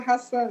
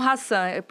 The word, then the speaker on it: Hasan: É Rosa Hassan, sim. Tá certinho. Tá certinho. Tem um S só, mas a pronúncia é, fica como Hassan.